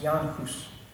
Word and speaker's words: Jan Hus: One of our kind of indirect spiritual ancestors, the Catholic priest Jan Hus, looks like John House, but it's pronounced Jan Hus, shared the Easter communion cup with the laity, despite knowing it would almost certainly lead to his execution as a heretic.